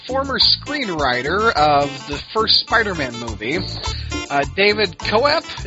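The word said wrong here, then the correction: Koepp: is that how you say it? He thinks there should be three different Spider-Man movies. – David Kep. – Kep, that's how you say it.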